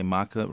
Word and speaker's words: maca: It's called Mecca or Maca. – Yeah, okay, Maca, right, okay.